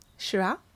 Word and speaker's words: Syrah: My first crush, S-Y-R-A. – Syrah.